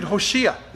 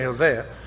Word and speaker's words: Hoshea: And that weird dude, Hoshea, is actually how you say it. We say Hosea.